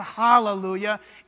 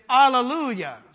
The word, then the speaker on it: Alleluia: You say, well, we say Hallelujah. Yeah, I know, but that's not how it's said in the original language. It's not Hallelujah, it's Alleluia.